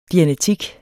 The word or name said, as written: dianetik